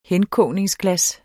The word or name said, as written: henkogningsglas